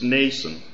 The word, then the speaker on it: Mnason: When you look at that word, it has an M in front of it, but when I looked it up in the concordance, the M is silent. So it's Nason, which is a new name.